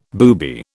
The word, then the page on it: booby: British English